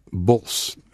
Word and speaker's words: Bosch: And his last name is pronounced Bos. The CH is silent in Dutch.